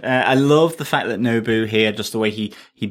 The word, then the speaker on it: Nobu: And even when Nobu has I've said it correctly this time. Not Nobu, not Queen Amidala's planet, but actually the character in Daredevil.